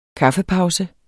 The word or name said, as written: kaffepause